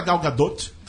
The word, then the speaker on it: Gal Gadot: Eu descobri como que ter lá nos Estados Unidos, em diversas partes dos Estados Unidos, porque no Instagram da Gal Gadot, tem que falar o T, eu aprendi isso.